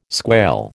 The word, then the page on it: squail: American English